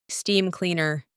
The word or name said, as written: steam cleaner